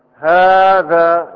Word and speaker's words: هذا: في مثلها أنتم، وفي هذا، هذا كيف ننطق؟ ها، هذا كيف تكتب؟ ها، هاء والذال مباشرة، ليس بينهما ألف، صحيح؟ صحيح. لكن كيف ننطقها؟ هذا، ها، في ألف في النطق موجودة، فهي تحذف.